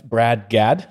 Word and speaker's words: Brad Gadd: Uh, today we have Dustin again as a kind of co-host, and we have Brad Gadd.